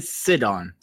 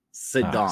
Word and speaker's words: Sidon: It's Sidon. – Sidon. Okay. – Yeah, like sit on. Sidon.